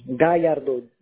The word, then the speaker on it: Gallardo: I have E-D-W-I-N, V as in Victor, A-L-L-A-R, D as in dog, O. Is that correct? – It's G, Gallardo.